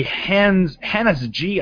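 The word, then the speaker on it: Hanns-G: And a company, Hannes G, I guess it's pronounced, H-A-N-N-S and a capital G, which presumably means it's German, has come out with a new line of square monitors.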